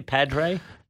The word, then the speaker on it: Padre: Is it, how do you say Padre? – Padre. There's some good names here. Bureau coffee. So you get the Padre, the Spanish.